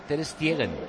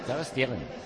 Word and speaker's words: Ter Stegen: Por ejemplo, Ter Stegen se dice Ter Stegen. Ter Stegen.